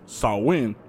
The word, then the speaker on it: Samhain: It originated with the ancient Celtic festival of Samhain, but it's pronounced Samhain, and people would light bonfires and wear costumes to ward off ghosts.